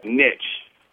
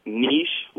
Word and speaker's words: niche: I pronounce the word niche, like hitch. This mm-hmm. is how I've always been taught to use it, although I think it is of French origin. – Right. – And some people do pronounce it niche, like quiche.